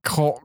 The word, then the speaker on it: choral: They like using coral? Coral? How do you say that word? Right now is one of those days where I can't. – Coral? – Coral! Choral. Choir? Like Choir.